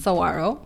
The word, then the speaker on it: Saguaro: So my last name is pronounced Saguaro.